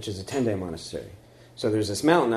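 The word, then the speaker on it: Tendai (天台): And the sect is called Tendai, which comes from a mountain in China called Tiantai. And so the Japanese pronunciation is Tendai, centered in Mount Hiei.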